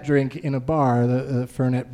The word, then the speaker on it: Fernet: And he goes, "As I sit there drinking my Fernet Blanca," and it's Fernet is how it's pronounced.